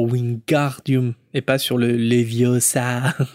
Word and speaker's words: Leviosa: C'est Leviosa et pas Leviosa.